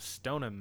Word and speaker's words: Stoneham: We have Ellen Keane, a librarian from Stoneham, Massachusetts.